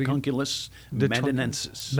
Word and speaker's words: Dracunculus medinensis: Dracunculus medinensis.